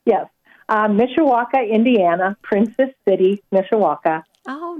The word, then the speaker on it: Mishawaka: Mishawaka, Indiana.